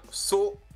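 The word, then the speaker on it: Sochan: Alors ça se dit, je suis sur Basketball Référence, et donc ils l'écrivent toujours en phonétique après dessous, et en prononciation, c'est bien Sohan. – On prononce pas le H, et puis on prononce pas non. Le C. Sohan.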